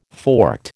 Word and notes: forked: American English

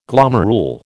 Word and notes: glomerule: American English

